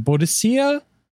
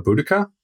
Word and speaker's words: Boudica: and I never know how to say her name, Boadicea? The, like, very... – Oh, Boudica? – Sure.